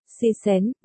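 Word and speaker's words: si xén (喜神): Nói chạy là để kiêng, hỷ thần, thần vui, tiếng Trung đọc là, si xén, người chết, tử nhân, thì đọc là, si rén.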